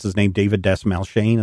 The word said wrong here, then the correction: Dastmalchian: Is that how you say his name? – Dasmalkian. – I always mispronounce it.